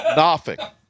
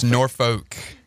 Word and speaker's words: Norfolk: Can you pronounce the way you say Norfolk again, please? – Norfolk. – Norfolk. – That's how they say it up there. – It's Norfolk.